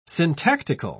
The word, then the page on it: syntactical: British English